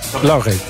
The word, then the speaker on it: Lauré: Zeg ik het zo goed? Ja, klopt dat, Lauré? – Lauré. – Ja, Lauré? Goed uitgesproken, ja?